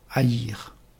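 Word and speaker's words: haïr (עייר): Voyons à présent le mot en hébreu, le jeune ânon, haïr. Haïr, c'est un haïn, un yod et un resh.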